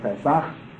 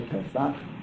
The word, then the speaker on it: Pesach: But Passover, Pesach, in Hebrew it's called Pesach. Or P-S-A-H, some spell it that way, Pesach, modern Hebrew, based upon the spelling in the Encyclopedia Judaica. The Ches sound, the Hebrew is written as an H in English, with a little dot on it.